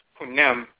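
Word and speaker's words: Khnum: They are named after the divinity, often spelled Kunum, pronounced it Kunem, because Kunem is the potter divinity.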